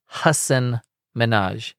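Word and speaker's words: Hasan Minhaj: But he said, he said, it's Hassan. It looks like Hassan. It's pronounced Hassan Minaj.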